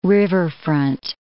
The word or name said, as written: riverfront